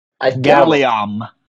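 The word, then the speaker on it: Galleom: Is it pronounced Gallum or Gallum? – Gallium. – Gallium, there we go.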